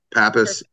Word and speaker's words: Pappas: Yeah, Pappas.